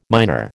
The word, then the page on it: minor: American English